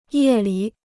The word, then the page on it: 夜里: yè li